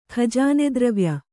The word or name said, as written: ಖಜಾನೆದ್ರವ್ಯ